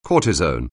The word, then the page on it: cortisone: anglais